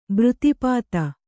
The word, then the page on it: ಬೃತೀಪಾತ: břtīpāta